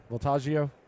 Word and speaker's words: Voltaggio: But coming up next, Food Network, Brian Voltaggio is going to – I said that right? – Yes, sir. – Voltaggio? – Mm-hmm.